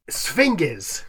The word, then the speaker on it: sphinges: I-N-G-E-S, yes. – Sphinges. Sphinges? – So it's a hard G? I would. – Sphinges. No, sphinges. It should be, and I was doing Greek. I was doing ancient Greek. It should be J.